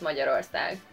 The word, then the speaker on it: Magyarország: En húngaro, Hungría es Magyarország, que no tiene nada que ver con inglés, con Hungary, Hungría, no, nada, no.